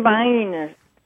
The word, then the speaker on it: Vienna: Well, you know, them viners.